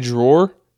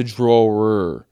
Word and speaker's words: drawer: My mom doesn't say drawer, like a thing that you pull out of a cabinet. She calls it a draw. D-R-A-W. And I said, Mom, spell that real quick. And she said, D-R-A-W. And I said, no, that's what you do with a pencil and colored pencils. It's a drawer.